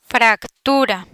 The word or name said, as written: fractura